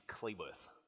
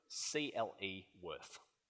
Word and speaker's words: Cleworth: My, um, my surname is Cleworth, C L E Worth. Uh, throughout my whole life, people have mispronounced my name. Um, it's particularly bad in high school. Every lesson, they you know read the roll and more often than not, I'd be called Charles Clayworth, Clemworth, or even my favorite, Clentworth.